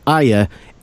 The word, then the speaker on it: Arya: And it is pronounced Arya. If you go off the way Maisie Williams pronounces it, my wife told me that when she met her and interviewed her, she said, "Hello, my name's Maisie Williams. I play Arya Stark."